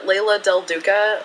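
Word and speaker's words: Leila Del Duca: And then I can be found at Layla Del Duca for Twitter, and my name is spelled weird.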